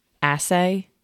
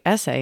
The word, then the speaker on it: assay: So we decided that there was enough brood in the hive that we were going to freeze that frame and do like a mite assay. Is that how you say it? Remember, I was like, essay, and you're like, that's not what it is.